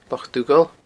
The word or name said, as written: Loch Dhughaill